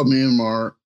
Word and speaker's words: Myanmar: So I found out, I saw Myanmar, not even know if I said it correctly, but you will. – You did. You got it.